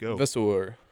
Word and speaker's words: Vasseur: Yes, Frederic Vasseur.